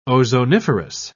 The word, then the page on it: ozoniferous: British English